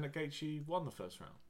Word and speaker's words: Gaethje: Gaethje and what he could do, or if you're pronounce it in the way that Sky Sports presenters do, as Gaffy and Gafja and whatever.